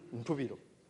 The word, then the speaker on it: Ntubiro: Can you pronounce that one? Ntubiro. Yeah. Ntubiro. That's right.